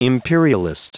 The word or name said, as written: imperialist